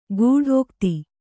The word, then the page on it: ಗೂಢೋಕ್ತಿ: gūdhōkti